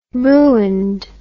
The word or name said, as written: ruined